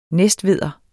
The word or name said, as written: næstveder